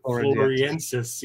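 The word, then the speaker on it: floresiensis: You know, well, that Homo florensis, I butchered it, floriensis, kind of the whole little island of tiny people.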